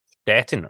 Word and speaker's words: Stettin: Stettin or Szczecin. – Szczecin. So he was playing with Szczecin. – They're the same city. One's the German pronunciation and one's the Polish.